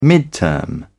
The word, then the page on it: midterm: British English